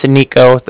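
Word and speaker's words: Niko: Well, my name is Nico, that's N-I-K-O.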